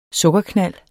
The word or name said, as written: sukkerknald